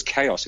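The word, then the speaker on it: KaiOS: It's an unusual word, but it's pronounced as chaos. It's K-A-I-O-S.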